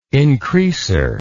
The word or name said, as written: increaser